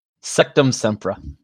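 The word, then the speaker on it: Sectumsempra: Sectum Sempra.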